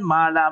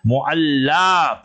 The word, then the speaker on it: Mu'alla: So she is buried there in Mu'alla, in the graveyard. Not Mala, Mala like a Malkop. You understood? Mu'alla.